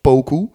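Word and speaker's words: Poku: Ja, volgens mij spreek je het uit als Pokku.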